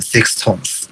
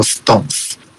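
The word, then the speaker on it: SixTONES: 这在日本现在是先说啦，就是这个日本人很讨厌的地方，他们的团名其实不念 Six Tones，他们团名做 Stones。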